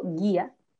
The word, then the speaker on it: guía: En cambio, si yo digo guía, yo digo guía, guía. Yo no digo guía, porque no me tocaría decir como en el acento en la A, guía.